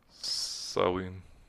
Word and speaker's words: Samhain: Anyway, it is widely believed that many Halloween traditions originated from the ancient Celtic harvest festivals, particularly the Gaelic festival Samhain. – Sowing. – Did I say it wrong? – It's easy to, because whatever. – Gaelic is spelled nothing at all like it's pronounced.